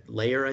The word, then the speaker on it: Loehr: And it's by Jim Lehrer, I think it's pronounced. It's LOEHR, and Tony Schwartz.